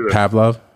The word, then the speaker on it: Pavlov: Remember the, I think it's Pavlov? Am I saying his name correctly? – Pavlov, yeah.